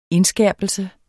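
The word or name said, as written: indskærpelse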